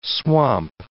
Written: swamp

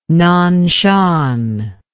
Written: nanshan

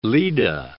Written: Leda

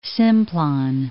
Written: Simplon Pass

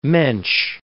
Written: mensch

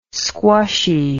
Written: squshy

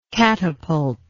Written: catapultic

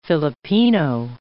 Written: Filipino

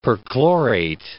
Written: perchlorate

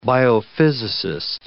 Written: biophysicist

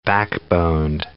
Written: backboned

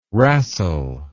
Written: rastle

